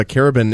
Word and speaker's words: carabin: By the way, Chris, producer Chris here, looked up what a carabin is. Carabin, I believe, is how it's pronounced in Montreal, in French, I should say.